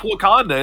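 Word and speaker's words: Waconda: But it's spelled differently. It's W-A-C-O-N-D-A. Yeah, it's Camp Wakanda.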